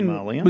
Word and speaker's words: Marlion: Is it Marleyan? – There's an I in there. I don't know whether it is a silent I, but it is spelled M-A-R-L-I-O-N. – And it is. Sorry, Marleyan.